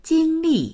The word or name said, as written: jing1li4